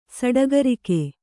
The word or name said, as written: ಸಡಗರಿಕೆ